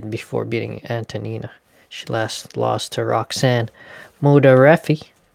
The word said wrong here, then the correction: Modafferi: Can't even say her name. Modafferi. There you go.